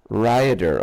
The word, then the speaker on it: Rhayader: I'm in a little town in mid-Wales called Rhaiada. – How do you spell that? – R-H-A-Y-A-D-E-R. – Rhaiada, okay.